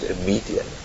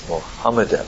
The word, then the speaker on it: Mohammedan: Not a median, like a comedian. No, it's Mohammedan.